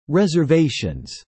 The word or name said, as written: reservations